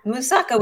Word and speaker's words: moussaka: One is, and help me because it's probably not going to pronounce it right, moussaka. – Yeah, moussaka with, yes. So the moussaka is also a Greek dish. I do it with a mushroom bechamel, which is on top. And the moussaka, I would say, is like a Greek lasagna.